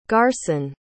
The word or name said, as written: garrson